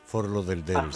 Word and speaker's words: Fort Lauderdale: Fort Lauderdale, no sé si lo pronuncio bien. Fort Lauderdale, sí, sí, sí.